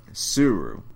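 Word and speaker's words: Tsuru: The Suru, because it's a silent T, much like in Tsunami.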